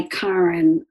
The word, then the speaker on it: Karin: And so by the way, I go by Karin. It's the Swedish pronunciation of Karen.